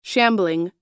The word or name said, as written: shambling